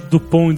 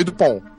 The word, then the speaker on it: Dupont: mesmo, que é e Dupont. É, Dupont com T e Dupont com D, mas você não pronuncia é. a, a, letra final, então.